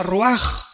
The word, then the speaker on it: ruach: Aquí se usa otra palabra hebrea, que es, se escribe ruach, con R de Raúl, A-C-H, se pronuncia ruach.